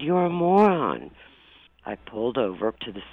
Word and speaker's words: Moran: You asked me to research the family name, M-O-R-A-N. And in Ireland, it's pronounced moron.